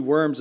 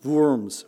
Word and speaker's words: Worms: And it's not really Worms, it should be pronounced Worms with a V.